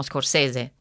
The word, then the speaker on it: Scorsese: Perché noi diciamo Scorsese, cioè per noi in italiano si legge così, invece in inglese naturalmente Scorsese con quella E finale che appunto si legge I, così